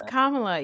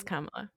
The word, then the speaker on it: Kamala: I have a Kamala problem. By the way, is it Kamala or Kamala? I actually don't know. – It's Kamala, you piece of shit.